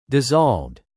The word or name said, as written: dissolved